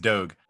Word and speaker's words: Doge: I think it's Doge. – Oh, it's Doge. It's D-O-G-E. All I know is like the memes are dogs, and so I was like, is it a silent E or whatever? – Yeah.